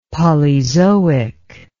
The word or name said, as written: polyzoic